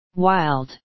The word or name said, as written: wild